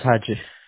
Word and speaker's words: Haji: My name is Haji. – Haji. – Yeah. – How do you spell it? H-A? – Haji. – H-A-J-I.